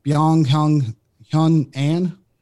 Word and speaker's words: Byung-Hyun An: Byung-Hyun An.